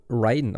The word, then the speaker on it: Raiden: Ja, entweder Raiden von Mortal Kombat, ne? Oder halt in Metal Gear Solid. Ich weiß nicht, warum das anders ausgesprochen wird. Geschrieben wird es ja gleich eigentlich, ja, oder?